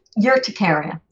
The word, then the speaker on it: urticaria: Okay, I know you wanted to talk specifically about eczema and urticaria. Have I said that right? – Urticaria. – Urticaria. So tell me about that.